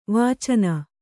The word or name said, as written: ವಾಚನೆ